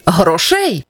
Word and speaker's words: грошей: Як правильно, бракує грошей чи грошей? Правильний варіант з наголосом на першому складі. Бракує грошей.